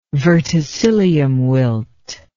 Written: verticillium wilt

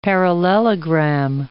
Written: parallelogram

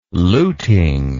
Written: luting

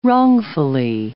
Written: wrongfully

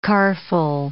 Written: carful

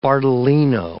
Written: Bardolino

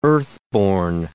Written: earthborn

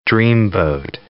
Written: dreamboat

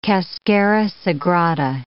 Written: cascara sagrada

